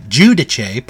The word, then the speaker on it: Giudice: Teresa Gudice, I think I pronounced that right, Giudice, pardon me, and her entire family.